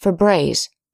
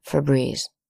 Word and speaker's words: Febreze: The company further reduced the spelling to only one E, which rather defies the laws of American English and should be pronounced Febreze, not Febreze.